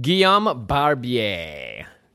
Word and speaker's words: Guillaume Barbier: That's actually how you say your name, and it's not... We were talking about this earlier. If it was like an Americanized version, it was actually like Guillaume Barbier.